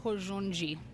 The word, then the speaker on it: Hozoji: So it's something like Hizoji. – Hizoji. – Something like that. I'm butchering it, surely. But it's already such an ordeal to introduce myself.